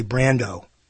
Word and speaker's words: Brandow: He wants me to say Brando. And I'm going to say Brando from now on, even though there's an A in there. Brando. The A is silent.